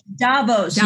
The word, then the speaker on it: Davos: Yeah, it's Davos. Named after where the World Economic Forum is held.